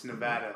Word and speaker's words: Nevada: It's Nevada.